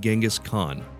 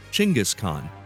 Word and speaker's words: Genghis Khan: Now, for the purposes of this episode, I'll be pronouncing his name Genghis Khan. Another correct pronunciation is the way it's pronounced in Mongolia, Chinggis Khan.